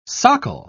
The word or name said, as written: socle